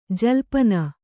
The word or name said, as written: ಜಲ್ಪನ